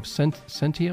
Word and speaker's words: Sentio: Today, I've got an old friend of mine, John Mark Ramsey, who is the CEO of Sentio. Did I say it right? – You're saying it right.